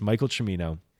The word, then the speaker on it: Michael Cimino: I for sure up until this point was saying Michael Cimino on this podcast, because that's the way that my brain read that when I was younger, and I've never, for whatever reason, never been able to get that pronunciation out of my head. But it's Michael Cimino.